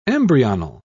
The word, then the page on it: embryonal: British English